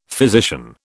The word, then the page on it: physician: British English